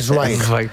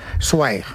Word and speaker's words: Zweig: Por el camino de bien, ahora hay que decir Zweig. – Zweig. – Zweig. Esteban Zweig. – Es así como se pronuncia en alemán.